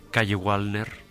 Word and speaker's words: Kalle Wallner: Kale es, es bueno, es en alemán, calle, se escribe calle con K, y Walner con doble L, calle Walner,